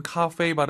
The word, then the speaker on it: 咖啡: 와, 3월호부터 하신 분들은 카페이 발음이 이제 낯설지 않을 것 같아요. 근데 발음이 좀 어려워요. 카페이가 아니라 카페이가 아니라 카페이.